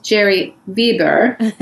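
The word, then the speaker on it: Gerry Weber: which is pronounced Jerry Weber in German.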